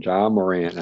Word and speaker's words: Ja Morant: No, Ja. I'm sorry. I always say Jay. I don't know why. Ja Morant.